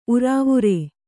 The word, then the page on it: ಉರಾವುರೇ: urāvurē